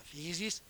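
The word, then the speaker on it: physis (φύσις): E siccome in greco natura si dice, come sempre, physis, è scritto fusi, ma si pronuncia physis,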